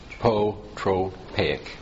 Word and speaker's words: apotropaic: But that would involve a relatedness to the soul, which this, how do you pronounce that word, atropotetic? – Apotropaic. – Yeah, that word.